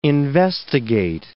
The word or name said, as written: investigate